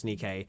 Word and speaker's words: Nike: So, yeah, I'm keen to see that, like, and again, I apologize for saying her name incorrectly, but it's Nike.